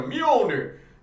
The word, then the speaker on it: Mjolnir: When Thor pops up in the Fantastic Four and he goes, "With my enchanted hand of Mjolnir," that's how you pronounce that.